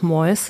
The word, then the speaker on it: Mois: Also erstmal hast du ihn, glaube ich, richtig ausgesprochen. Er yes. nennt sich selbst auch Mois.